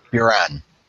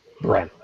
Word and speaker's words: Buran: I pronounced it Bhutan. Do I have it incorrect? – Buran. – Buran, okay.